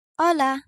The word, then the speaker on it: hola: Letter H is always silent. For example, hola, hola.